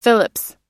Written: Phillips

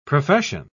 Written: profession

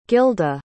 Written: Gylda

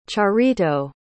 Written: charito

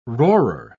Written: roarer

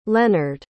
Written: leonard

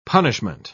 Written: punishment

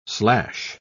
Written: slash

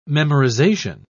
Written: memorization

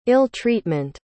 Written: ill-treatment

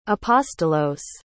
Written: apostolos

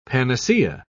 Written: panacea

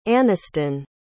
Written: anniston

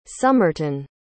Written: sumerton

